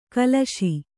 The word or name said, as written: ಖುಲಾಸು